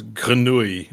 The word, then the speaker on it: grenouille: You don't pronounce the L's when it's two L's. Like frog in French is grenouille, but there's two L's. Oh, well. O U I L L E.